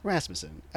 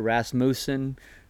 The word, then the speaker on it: Rasmussen: My dad, when he was a kid, was Moose for Rasmussen. Um, but yeah, the correct pronunciation is Rasmussen.